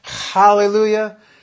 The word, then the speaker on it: Hallelujah: When it's spelled with an H, that's a nod to the Hebrew spelling and pronunciation, Hallelujah. And what's with A, that's a wink and a nod to the Greek spelling and pronunciation, Alleluia.